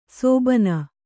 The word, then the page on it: ಸೋಭಾನ: Kannada